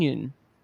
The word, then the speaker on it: Yeun: I've been pronouncing it wrong this entire time. I heard an interview. It is Steven Yeun.